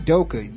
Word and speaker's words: Udoka: LeBron and Udoka, yes, I said his name correctly, thank God.